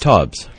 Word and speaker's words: Taubes: Taubes.